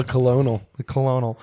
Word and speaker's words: colonel: I tried to read colonel the way it's spelled just now. – A colonel.